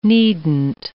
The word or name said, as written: needn't